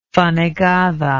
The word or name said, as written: fanegada